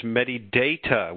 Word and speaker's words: Medidata: And that's, just so everybody remembers, MediData with a long A.